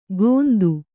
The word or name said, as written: ಗೋಂದು